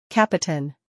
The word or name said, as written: Capoten